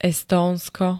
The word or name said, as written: Estónsko